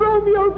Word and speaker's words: Romeo: R-O-M-E-O, is pronounced disyllabically, Romeo, not Romeo.